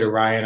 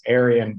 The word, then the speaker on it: Arion: You know, there's a press up here in San Francisco called Orion Press, or I pronounce it Orion. I think that there's a pronunciation that's Aryan, but I prefer to call it Orion, A-R-I-O-N.